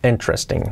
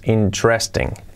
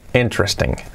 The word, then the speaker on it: interesting: Cuidado con la palabra interesting, que es interesting, no es interesting. Interesting, ¿ok? Con acento en la primera sílaba.